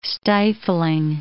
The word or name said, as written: stifling